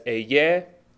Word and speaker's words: Ehyeh: The Hebrew says, "Eyeh Asher Eyeh." Eyeh is spelled E-H-Y-E-H, Eyeh.